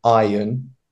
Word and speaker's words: iron: Now, this is quite a difficult word to pronounce because a lot of students, when I used to teach, found it very difficult to pronounce the word iron because they would say iron or iron with an R. So the R is quite silent in that. So we just say iron.